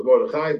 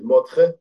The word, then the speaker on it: Mordechai: Some people call it the Mordechai, but the real correct pronunciation is Mordechai. Anyway, that's the correct pronunciation.